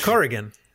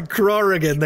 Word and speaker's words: Corrigan: So I made Jeremy Usborne with the U, and I made Mark Corrigan. – But Mark Corrigan. – Yeah, sorry, Corrigan.